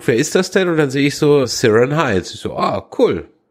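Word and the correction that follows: Ciarán: Oder Kieran, ja. Ist ja, glaube ich, jetzt ausgesprochen. Kieran Hines.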